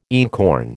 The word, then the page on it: einkorn: American English